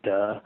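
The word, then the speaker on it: duh (德): unfortunately in modern Mandarin, is pronounced duh, It's always fine, kind of awkward, but I think the best translation is something like charismatic power.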